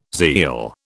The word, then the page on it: zeal: British English